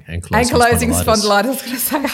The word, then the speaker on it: ankylosing spondylitis: Thanks for that introduction and well done on pronouncing ankylosing spondylitis.